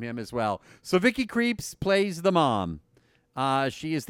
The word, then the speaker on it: Krieps: We'll be talking with Vicky Krieps. She's got the best last name, by the way, and it's spelled K-R-I-E-P-S. Not like creeps, like, you know, Halloween.